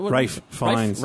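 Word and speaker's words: Ralph Fiennes: So Ralph, how do you pronounce it? – Rafe Fiennes.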